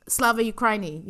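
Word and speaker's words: Slava Ukraini (Слава Україні): Slava Ukraine hang on how do you pronounce it I actually wrote this down um, Slava Ukraini you know the the kind of uh,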